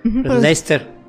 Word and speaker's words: Leicester: Se escribe Leicester. Leicester. – Leicester. Ajá, no, bueno, ya ven los ingleses.